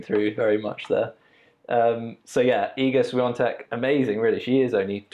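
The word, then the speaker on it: Świątek: So obviously we had Iga Swiatek, is, I think that's how you pronounce it, even though when you read it, it literally doesn't look anything like that.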